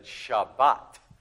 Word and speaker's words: Shabbat: Like in Sephardic, which is probably more like ancient Hebrew, Sabbath would have been said Shabbat, with a hard T sound at the end, And Ashkenazi pronunciation,